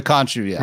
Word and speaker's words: Khonshu: And Bushman kills the archaeologist and kills Mark Spector or hurts him, which then Mark Spector becomes Moon Knight from the Khonshu. Is that how we're pronouncing it? – Yeah, Fist of the Khonshu, So, yeah, he's pretty much like the origin story villain.